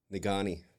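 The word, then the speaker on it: Negaunee: Is that how you say it? Nagani? – Nagani. – We say Nagani down here, but Marquette, Nagani area.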